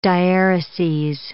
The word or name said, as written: diaereses